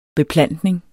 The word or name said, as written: beplantning